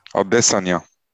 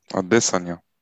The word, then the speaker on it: Adesanya: Also äh, auf jeden Fall der letzte Kampf, das war ja Adesanya, ich kann den Namen sehr schlecht aussprechen. – Adesanya. – Adesanya. – Adesanya. – Ja, genau.